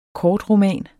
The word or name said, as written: kortroman